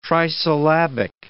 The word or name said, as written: trisyllabic